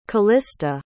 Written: calista

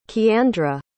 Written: kiandra

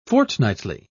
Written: fortnightly

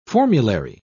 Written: formulary

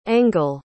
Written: engle

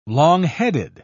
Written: longheaded

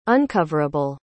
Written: uncoverable

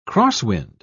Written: crosswind

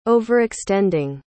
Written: overextending

